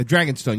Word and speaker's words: Dragonstone: A Dragonstone.